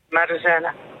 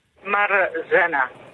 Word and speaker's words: Marzena: Marzena. – Zeg ik het goed? Is het Marlena? – Marzena. – Ah. – Marzena.